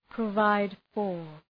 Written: provide for

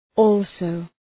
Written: also

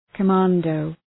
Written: commando

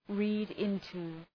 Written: read-into

